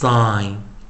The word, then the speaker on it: sign: I I，所以 S 开头，s s s sign sign sign，好，注意它就是说它发的是什么，很特别啊，特别 very 啊，特殊的一个发音，发出长元音，S I G N sign sign sign，啊，同学们啊，这个循环我们就教了啊，那大家。